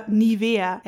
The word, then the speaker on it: Nivea: Tinted moisturizer cushion made by the German brand Nivea, or Nivea as we pronounce it here in Germany.